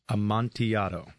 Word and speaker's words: Amontillado: This one is an Amontillado, and everyone should say this. It is Amontillado. – Amontillado. – Like, because Montiato. It is one of the biggest mispronounced words.